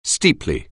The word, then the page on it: steeply: anglais